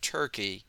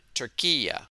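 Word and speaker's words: Türkiye: By the way, the country of Turkey is no longer pronounced Turkey, like the bird, but Turquia.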